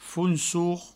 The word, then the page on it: fonsor: founsour